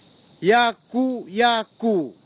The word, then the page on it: iaku iaku: kwamera; Tanna